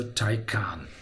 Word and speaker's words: Taycan: Der Porsche Taycan. Wie spricht man den? Tay wie Thailand und Kahn wie der ehemalige Torhüter von Bayern München. Also Taycan.